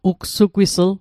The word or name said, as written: ugsugwisl